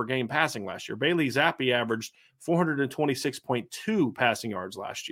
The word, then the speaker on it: Zappe: It's how you pronounce his name, correct? Did I say that Zappe. Correct? That, you got it.